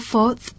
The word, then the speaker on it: fourth: เวลาออกเสียงก็จะออกว่า fourth นะคะแลบลิ้นแล้วก็แตะฟันนิดๆออก th เบาๆนะ